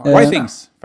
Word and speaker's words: Withings: Och det är företaget Withings, tror jag man uttalar det. – Withings, faktiskt. – Withings. – Withings, ja. Är det så? – Ja.